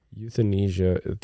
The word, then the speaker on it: euthanasia: Euthanasia, right? – Yeah, that's how it's written. Euthanasia. All right, so that's how we're going to pronounce it.